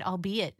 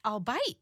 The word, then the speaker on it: albeit: was hosting, and he had used it in a spot for one of the programs coming out and said, albeit. And I was like, wait, what? And I looked at the spot, and I was like, no, that's albite. Oh, no, have I been saying it wrong in my head this whole time?